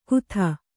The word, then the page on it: ಖುದಾ: khudā